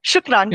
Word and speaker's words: shukran: And is it, am I pronouncing this correctly, shukran? – Yeah, shukran. Yeah.